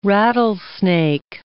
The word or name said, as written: rattlesnake